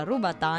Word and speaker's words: robatà: E la forma più antica di grissino è il robatà, si pronuncia rubatà, infatti ho appena sbagliato, significa rotolato, ed è lungo tra i 40 e gli 80 centimetri.